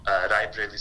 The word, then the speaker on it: Rai Bareilly: But I want to counter you and ask you, so here you are contesting from Rai Bareilly Serdar.